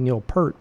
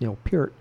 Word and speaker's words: Peart: And then everybody was saying, oh, you know, you don't say Neil Peart. That's not right. Even though that's what all of us have been saying forever. But now it's the correct pronunciation is Neil Peart, and the correct pronunciation for the Lovin' Brothers is the Louvin Brothers and Charles Louvin.